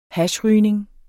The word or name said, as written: hashrygning